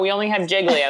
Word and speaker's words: Gigli: It's spelled G-I-G-L-I. – Yeah, Jiggly.